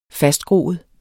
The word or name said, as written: fastgroet